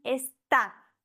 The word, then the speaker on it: está: Hier haben wir aber ein Wort, das auf Vokal endet, das heißt, die vorletzte Silbe würde betont werden, wenn da nicht der Akzent drauf wäre. Und dadurch sagt man está.